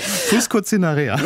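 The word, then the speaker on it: fuscocinerea: Die Formica fusconicera macht sich in Mitteleuropa breit. Ich habe es garantiert nicht richtig ausgesprochen. – Fusco-Cinerea.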